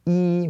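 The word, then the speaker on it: yi (一): Okay, so one is normally pronounced as yi when we only talk about the number one.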